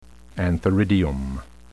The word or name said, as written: antheridium